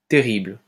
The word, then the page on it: terrible: French